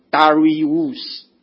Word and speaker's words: Darius: 那个是大流士，是因为以后的这个发音把它改成大流士，其实是原音呢是大利乌 Darius，大利乌的这个字眼啊，这是他的称谓了，亚哈水鲁王称谓。